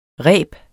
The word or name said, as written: rebets